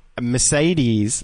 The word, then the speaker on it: Mercedes: Unfortunately, just today, I heard the same fact of Mercedes has three E's each. Oh, or is it Mercedes? Yeah, three E's and they're all pronounced differently.